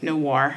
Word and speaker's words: noir: Like, no, it is Noir.